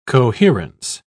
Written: coherence

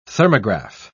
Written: thermograph

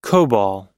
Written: cobol

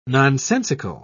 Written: nonsensical

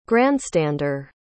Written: grandstander

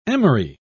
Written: emery

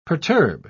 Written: perturb

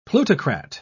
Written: plutocrat

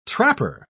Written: trapper

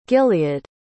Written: gilead

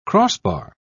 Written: crossbar

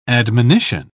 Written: admonition

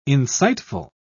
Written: insightful

